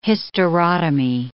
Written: hysterotomy

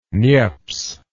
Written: Niepce

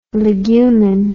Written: legumen

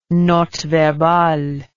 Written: note verbale

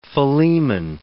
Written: Philemon

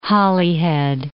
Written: Holyhead

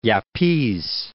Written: Yapese

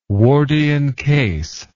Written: wardian case

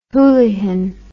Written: hoolihan

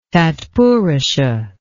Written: tatpurusha

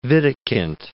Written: Wittekind

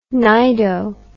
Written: cnido

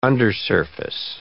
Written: undersurface

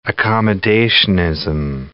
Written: accommodationism

